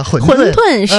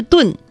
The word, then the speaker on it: 馄饨: 哎，豁然开朗，这个纠正读音呢混沌是正确读音、不是炖。